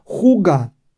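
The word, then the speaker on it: hygge: Se llama, lo que os he dicho yo, aunque se pronuncia, juga.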